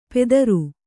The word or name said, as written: ಪೆದರು